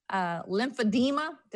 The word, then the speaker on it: lymphedema: We were just talking about lymphedema.